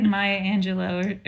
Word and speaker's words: Maya Angelou: I want to say like Maya Angelou or did I say her name right? – Yes.